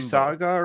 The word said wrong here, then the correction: Usagi: or something. It's Usagi. Usagi. Yeah, it's basically like bunny. I think it's bunny in Japanese.